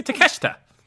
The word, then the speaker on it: Takeshita: Can't we just talk about the upcoming Fyter Fest and how Takeshita, am I saying that right? It's going to Shaw. Can you help me teach? How do you help me say it properly? – Yeah, Konosuke Takeshita. – Takeshita.